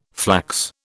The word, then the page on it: flax: British English